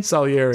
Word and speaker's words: Salieri: Salieri.